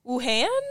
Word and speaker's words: Wuhan: They say the root city was the city of Wuhan? – That sounds correct pronunciation.